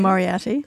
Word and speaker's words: Moriarty: Moriarty, how do you say it? – Moriarty. – Thank you.